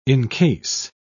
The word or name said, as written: encase